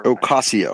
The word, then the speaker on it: Ocasio: And the Alexandria Octavio Cortez, I can't pronounce her. – Ocasio. – Name. Ocasio. Thank you very much.